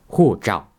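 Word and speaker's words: 护照: その時に言うのが護照っていう、こういう発音なんです。これは護っていう HU の発音を言ってるんですね。でこの時にどうですか、空気の音は聞こえないですよね。もう一度言いますよ。ほうじゃ。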